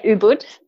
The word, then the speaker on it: Ubud: Or not Bali as such, is it Ubud? – Yeah, Ubud. – Ubud. Yeah. Can never pronounce that properly.